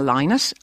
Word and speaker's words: Lynott: Well, my next guest this morning is Philomena Lynott. And that's how you pronounce it, as she just told me.